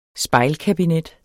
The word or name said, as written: spejlkabinet